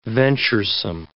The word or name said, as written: venturesome